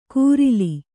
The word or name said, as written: ಕೂರಿಲಿ